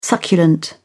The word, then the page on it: succulent: British English